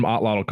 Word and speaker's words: atlatl: And where does the term atlatl come from? – Atlat is how you pronounce that. It comes from the Aztec language, Nahuatl.